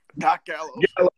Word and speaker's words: Gallows: He asked, you know, Doc Gallows. – You said it right the first time.